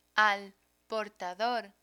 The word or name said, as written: al portador